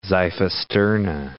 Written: xiphisterna

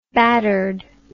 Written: battered

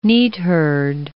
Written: neatherd

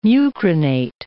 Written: mucronate

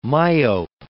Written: myope

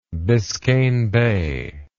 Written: biscayne bay

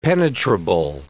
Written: penetrable

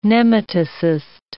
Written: nematocyst

